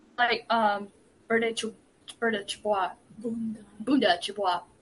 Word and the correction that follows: Chibwe: I can't pronounce it. Sorry. – That's all right. Bunda Chibwe. Yeah, Bunda, who has been called to join the first presidency.